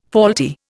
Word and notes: faulty: Indian

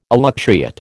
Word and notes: elutriate: American English

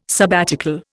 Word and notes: sabbatical: Indian

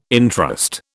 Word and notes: entrust: British English

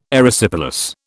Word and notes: Erysipelas: British English

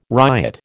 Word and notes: riot: American English

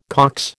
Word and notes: Cox: American English

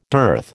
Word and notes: dearth: American English

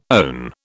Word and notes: own: British English

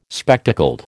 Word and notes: spectacled: American English

